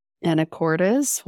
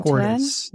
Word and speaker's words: Anacortes: And then on the 8th, you move on to a town, I'm hoping I'm pronouncing this correctly, Anacortes. – Anacortes, Washington. – Anacortes, yes.